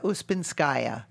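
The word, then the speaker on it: Ouspenskaya: I think she was always playing like How do you pronounce, say her name again? – Maria Ospenskaya.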